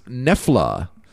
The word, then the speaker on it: Knoephla: Who lives in North Dakota, and this is pronounced Nefla, and it is a thick German soup with dumplings. – It's spelled K-N-O-E-P-H-L-A.